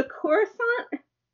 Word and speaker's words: Coruscant: So I chose the, and I asked you how to pronounce this multiple times, but it's the Coruscant? – Yes.